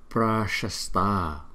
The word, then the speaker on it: Prashasta: Prashasta, pra, pra. Cái chữ này chúng ta đừng đọc là bra, mà đọc là bra, bra.